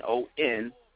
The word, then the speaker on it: NonFixion: You know, I put an X up in the nonfiction, so I spell it with an NONFIXION.